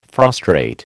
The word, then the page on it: frustrate: American English